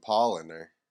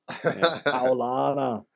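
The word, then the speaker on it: Paulaner: I only know the German way to say it now. How would you say that there? – I'd Americanize it by saying Pauliner. – Pauliner. Paulana. But isn't it funny, you know, like as soon as I saw it, now I know the German way of saying it.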